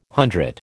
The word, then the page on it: hundred: American English